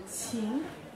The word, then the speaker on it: qin: The one I just played, the smaller one called the qin, Q-I-N.